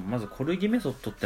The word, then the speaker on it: 骨気: コルギメソッドっていうのは、なんか、コルギ療法のベースとなったコルギ法、まあ、骨の木って書いてコルギって読む